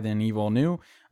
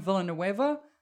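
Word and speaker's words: Villeneuve: I hate the fact that you said his name properly, because I call him Villanueva, and now I can't. – Gross. That's not how you pronounce it. He's French. Or Canadian? – He's Canadian. – Yeah, he's Canadian French.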